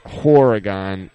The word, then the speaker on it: Horrigan: he's pronouncing it Horrigan, as in like the whore word. Not horror.